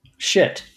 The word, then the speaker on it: Schitt: Shit. – Okay, so it's not spelled the way we're used to that word, S-C-H-I-T-T, which makes this a name and not a curse word.